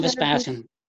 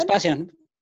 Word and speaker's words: Vespasian: So John, why don't you Vespasian. – Say that again? – Vespasian.